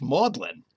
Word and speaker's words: Magdalen: And Kathy and I can tell you it's pronounced Maudlin. The G and the D disappeared in English for the college name at Oxford, both at Oxford and Cambridge.